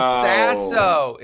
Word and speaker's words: Sasso: Will Sesso. Will from Sesso. Mad TV. – Oh, Will Sasso. Is that how you say Sasso? Yeah. Is Sasso his the way you say his name? – I think it's Sasso. – It's probably Sasso.